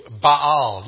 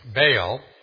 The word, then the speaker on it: Baal: Notice the name Baal, or technically in Hebrew, Baal. You may have one of those Bibles that puts it in different syllables with an accent. So it's Baal, or we'd say Baal.